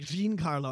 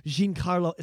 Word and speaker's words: Giancarlo: It's such a, I'd say like Giancarlo, I think is how you say it. When they say it at Celebration, they go, Giancarlo Esposito, I think is how they said it.